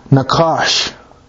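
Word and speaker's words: nakash (נחש): the word serpent in Hebrew is pronounced nakash, and it's a snake or a serpent.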